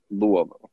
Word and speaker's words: Louisville: But next milestone in my life was 2012, July, Louisville. I said it right this time. Louisville, Kentucky. – Oh, Louisville. Yeah.